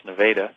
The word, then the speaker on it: Nevada: Yes, we're in a small town in the center of Iowa called Nevada, spelled just like Nevada, but we pronounce it correctly here. It's Nevada.